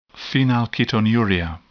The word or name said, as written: phenylketonuria